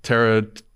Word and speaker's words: Pteranodon: All right, AJ Brown is the, I can't even pronounce it, Pteranodon.